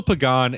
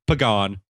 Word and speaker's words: Pagan: Angel Pagan is the outfielder for the San Francisco Giants. He pronounces it Pagan, not Pagan.